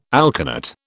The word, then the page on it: alkanet: British English